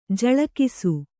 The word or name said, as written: ಜಲೌಕಸ